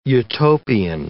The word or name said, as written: utopian